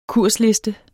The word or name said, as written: kursliste